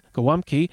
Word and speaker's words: gołąbki: And I looked it up, and the reason I guess I couldn't find it at first is because it's spelled like golabki, but that is the U.S. kind of take on the word. It's gołąbki.